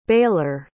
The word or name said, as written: baler